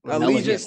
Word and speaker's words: Allegiant: But nigga, what I saw this weekend at SoFi and what I saw at Elegant Stadium, is that how you pronounce it? Elegant? – Allegiant Stadium. – That boy said elegant.